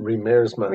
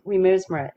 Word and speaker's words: Riemersma: How do you say her name? – Riemersma. – Rem- Riemersma. It's a Dutch name, and she, yes, it's, a, it's she says it is a difficult surname,